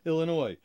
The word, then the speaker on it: Illinois: Illinois, please don't pronounce the S.